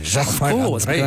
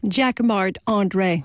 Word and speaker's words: Jacquemart-André: Jacquemart-André. You say it like André. A Frenchman. – Jacquemart-André. Oh, I'm French. So Jacquemart-André. – And say it so we can understand it. – Jacquemart-André.